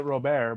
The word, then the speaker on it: Robert: He probably pronounced it Robert, but it's Robert. – He's nasty. – It's Robert to us white people.